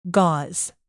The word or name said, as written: gauze